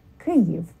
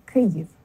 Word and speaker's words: Kyiv: And it helps if you smile a little bit to say it, especially on the first syllable, Kyiv, because you have to spread your lips a little bit to say it properly, Kyiv. And the first syllable is stressed.